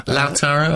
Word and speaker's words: Lautaro: Is that how you say his name? Lautaro? – Lautaro, I think.